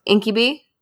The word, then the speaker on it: incubi: So basically the idea that shadow people are ghosts, poltergeists, demons, incubi.